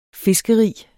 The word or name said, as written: fiskerig